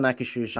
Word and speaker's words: Akershus: But I've never done Akershus.